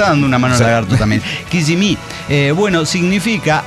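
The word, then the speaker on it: Kissimmee: Pirata, Kisme se pronuncia Kisimi. – Kisimi. – Kisimi. O con acento en la segunda I.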